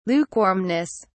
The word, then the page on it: lukewarmness: British English